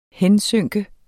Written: hensynke

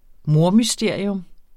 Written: mordmysterium

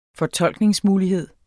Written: fortolkningsmulighed

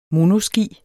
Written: monoski

